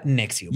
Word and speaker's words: NXIVM: Hoy les voy a contar sobre el líder del culto Keith Raniere y su club de abuso sexual Nexium. Se escribe N-X-I-V-M. Voy a llegar a por qué se llama así. – Por mamar. – Pero se pronuncia Nexium.